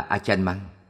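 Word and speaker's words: Ajahn Mun: Thuở thiếu thời, ngài Pra Achan Mun, đọc là Achan Mang, là vị thiền sư lỗi lạc nổi danh nhất trong thời của ngài.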